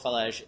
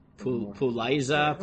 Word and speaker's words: Falej: So the Mordechai quotes Rav Shmuel of Falej, who I know, I'm aware, Rav Shmuel Falej is, that's how you pronounce that, by the way. I know it says, Pei Vav Lamed Yud Yud Zayin Aleph, which I guess you could read as Puliza, Puliza. Oh, so in English, it's F-A-L-A-I-S-E, it's Falej.